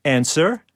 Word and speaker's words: answer: Silent P, right? Or answer? – W. – W yeah.